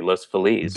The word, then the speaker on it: Los Feliz: I appreciate the fact that you pronounced it correctly, Los Feliz.